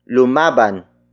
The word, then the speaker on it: lumaban: Lumaban, siya, para, sa, atin.